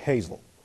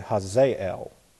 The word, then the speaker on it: Hazael: I look at it, it looks like Hazel, but it's actually Hazael. You got to put the emphasis on the right syllable.